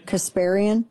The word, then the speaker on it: Kasparian: So I don't think I'm going to say her name right. Anna Kasparian. – Kasparian.